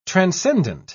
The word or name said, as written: transcendent